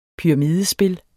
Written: pyramidespil

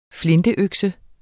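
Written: flinteøkse

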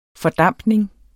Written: fordampning

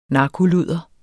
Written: narkoludder

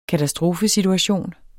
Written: KATASTROFESITUATION

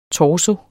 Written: torso

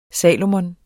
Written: Salomon

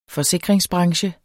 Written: forsikringsbranche

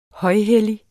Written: højhellig

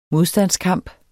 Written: modstandskamp

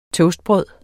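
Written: TOASTBRØD

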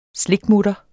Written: slikmutter

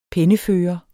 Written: pennefører

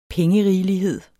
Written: pengerigelighed

